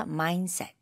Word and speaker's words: mindset: 중간에 mind, D가 있잖아요. – 네. – 근데 여기서는 D를 많이 발음 안 해도 돼요. 그래서 mindset.